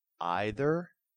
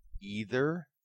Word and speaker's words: either: There are two correct pronunciations of either and neither, either and neither, starting with either a long I or long E sound.